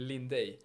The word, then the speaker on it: Linday: Det är väl Lindej. – Lindej? – Ja, det brukar vara lite knepigt med det uttalet. Det har varit i alla år, men eh, ja, det är det rätta i alla fall.